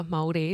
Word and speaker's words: Māori: I've got cousins who are Māori, is that how you say it? – Yeah.